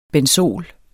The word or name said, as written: benzol